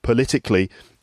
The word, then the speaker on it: political: And then the adjective political, the word stress is on the second syllable, political, and then the adverb politically, for example, politically correct, politically, the stress is on the second syllable.